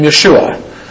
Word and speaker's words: Yeshua: was the Hebrew name Yeshua. We would pronounce it today as Joshua, but it's the same meaning as Jesus.